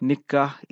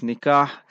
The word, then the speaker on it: Nikah: And it's Nikah, Noon, Kaf, Alif, Ha, Nikah. So as Mufti Saab says, people spell it Nikah. So it's not Nikah, it's Nikah. And that will be our program today.